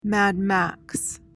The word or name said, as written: Mad Max